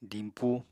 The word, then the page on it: dimpu: apatani